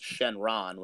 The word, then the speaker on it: Shenron: In Japanese, it's pronounced Shenron, which is what, like, it's called in the English dub as well.